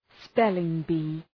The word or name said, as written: spelling bee